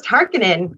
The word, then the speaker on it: Harkonnen: So it's really interesting though to hear everybody talk about it because I was like, it's just Harkonnen. Like that's how you pronounce it, you know?